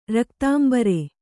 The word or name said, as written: ರಕ್ತಾಂಬರೆ